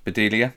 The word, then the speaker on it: Bedelia: So, also returning, we've got Bonnie Belladia. Did I say it right this time? Bedelia. There we go.